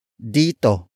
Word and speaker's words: dito: Dito. Dito. Dito is spelled as D-I-T-O.